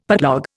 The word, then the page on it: putlog: Indian